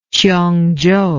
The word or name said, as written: qiongzhou